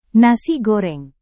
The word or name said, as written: nasi goreng